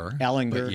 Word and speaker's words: Ehlinger: Ehlinger.